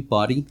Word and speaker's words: Bodie: First of all, am I saying it properly, Bodie Island? – You are saying it correctly. It is Bodie Island Lighthouse. – Mm-hmm. As I'm sure all our listeners know, it's spelled B-O-D-I-E, which I think most people would automatically think is Bodie.